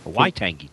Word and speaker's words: Waitangi: So happy Watangi Day. Waitangi Day.